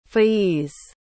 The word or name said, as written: faiz